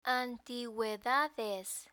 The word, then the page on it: antiguedades: spanish